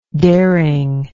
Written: daringness

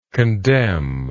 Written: condemningly

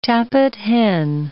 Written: tappit hen

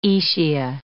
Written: aecia

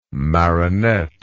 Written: Marinette

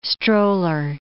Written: stroller

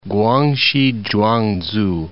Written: Guangxi Zhuangzu

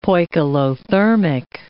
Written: poikilothermic

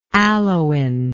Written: aloin

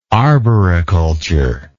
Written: arboriculturist

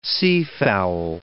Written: seafowl

